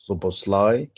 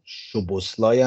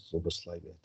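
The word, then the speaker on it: Szoboszlai: زوبوسلای که من دیدم بعضی جاها شوبوسلای هم تلفظ میکنن که خب خوب نیست اون زوبوسلای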